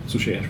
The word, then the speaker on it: Suchet: mit, mit, 70 Folgen mit ähm, David Suchet, Suchet spricht man aus, ja, die wirklich gut ist.